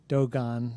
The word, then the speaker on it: Dogon: I had learned some time ago that he was investigating the symbolism of ancient languages, particularly the Dogon, or Dogon, as some pronounce it, in, I guess it's Eastern Africa towards Timbuktu.